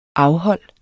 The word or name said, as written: afhold